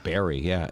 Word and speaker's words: Barre: Well, what it turns out, Barrie, yeah, it's spelled B-A-R-R-E.